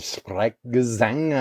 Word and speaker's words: Sprechgesang: It's actually like this technique called, it's German Sprechgesang, I think is how you pronounce it.